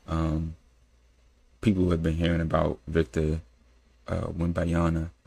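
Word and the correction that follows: Wembanyama: I think that's how you say his name. Wimbanyama. – Wimbanyama.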